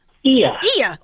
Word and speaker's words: Oia: Ia.